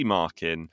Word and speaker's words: Markin: And in that final will be the man that we've just heard from, Jesse Markin. And I asked you, how do we pronounce Jesse's surname? And you said... – Markin. It's marking your homework without the G.